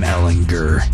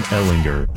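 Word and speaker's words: Ehlinger: It is Sam Ellinger. Hard G. Not a J. – Sam Ellinger.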